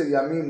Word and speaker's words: yamim: Whenever you see the word yamim with a dagesh in the mem, it means oceans. A good Baal Kore will have to know that that's how to pronounce it. If you just say yamim without stressing the mem, it means days or years.